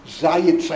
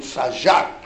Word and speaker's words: Zajac: Nachum Shmayahu Zayitz, I think he pronounces it, I read it Zajac, Z-A-J-A-C.